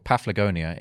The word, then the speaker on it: Paphlagonia: Google informs me that it's called Paphlagonia in northern Turkey.